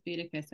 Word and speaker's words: Boudica: Number two, and honestly, I don't even know if I'm pronouncing this correctly, but Boudica. – Yeah, I always pronounce it as Boudica, so I think that's right.